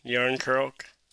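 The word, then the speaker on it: Järnkrok: Then they had William Nylander, I don't know, somebody named how do you pronounce that? Yarn Crocker? – Oh, the former friend, Yarn Croak.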